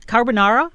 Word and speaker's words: carbonara: All right, so um, i am going to do our, our ID, and then we can get into the car carbonara. Did I say it? – Yes, you nailed it.